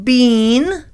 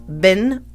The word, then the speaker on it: been: But in the United States, we pronounce it bin or ben. I have been there and now I'm back. I have been to Canada. I had a great time. Have you ever been to Canada? We pronounce it like it's a short I or a short E.